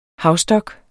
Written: havstok

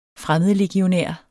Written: fremmedlegionær

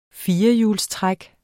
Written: firehjulstræk

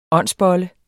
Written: åndsbolle

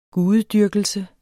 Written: gudedyrkelse

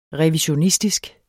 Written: revisionistisk

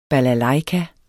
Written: balalajka

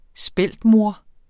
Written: speltmor

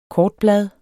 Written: KORTBLAD

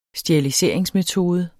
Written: steriliseringsmetode

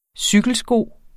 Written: cykelsko

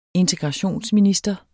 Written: integrationsminister

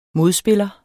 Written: modspiller

